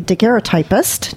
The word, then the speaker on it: daguerreotypist: It's illustrated by a daguerreotypist. Is that how you say it? – Daguerreotypist. – Daguerreotypist. Yeah, exactly.